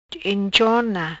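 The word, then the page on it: Enchona: Patua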